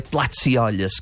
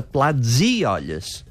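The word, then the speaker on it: plats-i-olles: És a dir, que faria aquest so sord i diria plats i olles, que no és la manera correcta de pronunciar-ho en català, que ha de ser plats i olles. Sonora, plats i olles.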